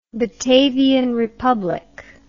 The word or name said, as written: Batavian Republic